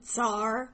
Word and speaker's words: Tsar: The other thing is, you're not going to hear us say the TS in Tsar. We know that it's pronounced that way, but it's affected to say that kind of thing in our accent, and it's just a distraction. So we're probably going to say Tsar, and we're not going to say Tsaritsa, which is what the Russians would have called her.